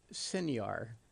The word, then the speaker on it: signor: An Italian term of address, according to Webster's here, showing a respect, and pronounced signor or signar, at least according to the words right in front of me.